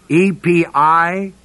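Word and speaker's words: epipotheō: The Greek word is epipotheo, long O.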